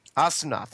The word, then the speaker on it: Asuna: Asuna.